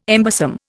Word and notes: embosom: Indian

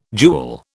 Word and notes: jewel: British English

